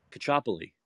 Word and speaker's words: Caccioppoli: How do you say your last name? – Kachopoli. – Kachopoli. Oh, okay. – That's it. Perfect. You got it.